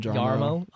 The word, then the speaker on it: Jarmo: Something along the lines was Jarmo's quote. – Did you just say Jarmo? – Yarmo.